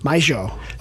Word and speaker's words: mayo: Oh, I was told not to pronounce it mayo. I was supposed to say Argentinian, mayo. Like that.